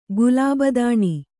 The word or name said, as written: ಕ್ಲೀಬತನ